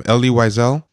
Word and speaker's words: Elie Wiesel: Elie Wiesel? – Yeah.